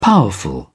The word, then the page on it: powerful: British English